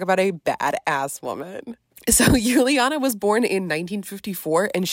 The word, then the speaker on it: Juliane: And her name is spelled a bit like Julianne. That's what we would say in English, but she's German. So that's why it's pronounced Juliana.